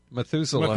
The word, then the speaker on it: Methuselah: How do you say it? – Methuselah.